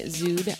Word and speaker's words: Szud: So check out my SoundCloud, Stephanie Zood on SoundCloud. – You want to spell that one? – Yes. S-Z-U-D is how you spell the last name.